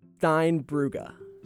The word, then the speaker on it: Steinbrugge: Steinbrugge.